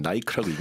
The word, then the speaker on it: Nike: 왜 미국 브랜드 나이키를 영국 사람들은 나이크라고 읽는지.